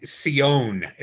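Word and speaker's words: Sione: Number six, Shone. Now, that's the Barbarian. Am I saying that right? – I think it was Sion is how they said it, but it doesn't.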